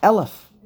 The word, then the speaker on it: Aleph: The next letter is the first letter, which is Aleph, which is pronounced Aleph.